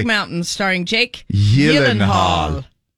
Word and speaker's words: Gyllenhaal: But it's Swedish. So no. It should be pronounced Gyllenhaal. Jake says, quote, the only two places it's pronounced correctly is in Sweden and at IKEA.